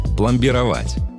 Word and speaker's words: пломбировать: Одно из них – пломбировать. Да, ударение в нем ставится именно так и никак иначе.